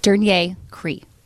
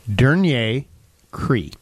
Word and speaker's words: dernier cri: I'm going to spell it for you. Okay. D E R N I E R. Denier. S- Denier. Cree. C R I.